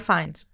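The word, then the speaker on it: Fiennes: Πώς λέγεται, Ραλφ Φίνς, ο Βόλτεμορτ. Πώς λέγεται. – Ραλφ Φάινς.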